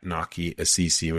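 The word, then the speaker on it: Nakiasisi: And literally all my handles are at Naki Asisi, which is N as in November, AKIASISI.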